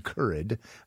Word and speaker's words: Currid: Currid, I think is how you pronounce it, C U R R I D.